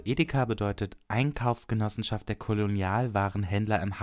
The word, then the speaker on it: Edeka: Kurz E.D.K. Und weil man das immer Edeka ausgesprochen heißt, heißt die Firma jetzt Edeka.